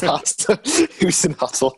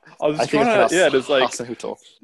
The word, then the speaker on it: Hasenhüttl: I think you butchered his name there. It's Hasenhüttl. Yeah. – Hasenhüttl.